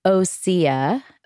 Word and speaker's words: Osea: Pronounced Osea,